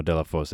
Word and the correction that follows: Delafose: It's it's actually pronounced Della Fus. Okay, so hopefully that's that's a little closer.